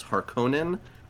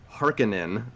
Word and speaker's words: Harkonnen: And when the when the movie and the novel opens, uh, spice has been mined for the last little while by House Harkonnen, or in the, these new movies, Harkonnen. So the pronunciations are going to be a little bit different depending on uh, what era you join this fandom.